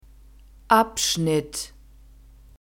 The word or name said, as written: abschnitt